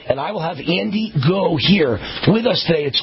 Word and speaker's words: Ngo: Andy Goh. I think that's how you pronounce it. The N is silent. N-G-O.